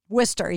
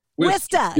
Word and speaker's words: Worcester: Did I said it right too? Worcester? – Worcester, yeah. Wista. Yeah, just take all the unnecessary letters out of there.